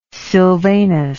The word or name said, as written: sylvanus